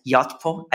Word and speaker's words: Yotpo: Doron is a director of infrastructure at Yotpo.